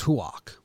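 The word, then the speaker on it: Houck: Maybe Hauk shove so the Sox fans talk about righty Chris Sale. – That one's actually pronounced Huak. – Huak. – Like Roark.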